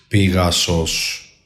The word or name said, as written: PIGASOS